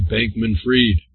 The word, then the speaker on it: Bankman-Fried: And we had a question, how do you pronounce it? Well, I think pending reversal on his appeal, I think we can now say his name is pronounced Bankman-Fried, not Bankman-Fried.